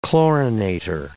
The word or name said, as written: chlorinator